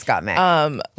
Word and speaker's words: Gottmik: Yes, Gottmik. Like Gottmilk.